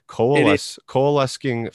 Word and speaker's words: coalescing: Coalescing filter. Am I saying that right? – It's coalescing filter.